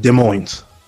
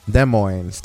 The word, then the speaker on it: Des Moines: This is Raw, episode 1543, which came to us from Des Moines, Iowa. I wonder if I said it correctly. It's the Wells Fargo area. Des Moines. – Des Moines.